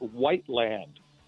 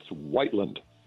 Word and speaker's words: Whiteland: It's not Whiteland. It's Whiteland. There's a big difference. – Whiteland. Okay. – You were pronouncing it Whiteland the other day, and it just sounded kind of awkward.